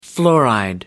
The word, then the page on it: fluoride: American English